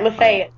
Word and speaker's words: Lafayette: But I did feel a distinct sense of place with Lafayette, as it is properly pronounced. – Right, Lafayette, Yeah.